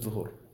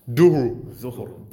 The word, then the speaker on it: Dhuhr: Z-U-H-U. – You can pronounce it any way you like, but it's just Zuhur. – Duhur. – D-H-U-R-H. – It's not D-U-H-U-R. – D-H-U-H-R.